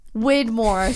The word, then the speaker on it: Widmore: But now I only know him as Whitmore. Widmore. Sorry. Why am I saying it wrong? – Whitmore.